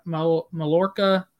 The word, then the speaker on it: Mallorca: Mallorca? – Yeah, Mallorca. – Maybe Mallorca. – Mallorca, sorry. – Mallorca, because it's they Mallorca. Don't pronounce the L's, right? – It's not the L. Yeah, it's Mallorca.